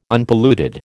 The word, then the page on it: Unpolluted: American English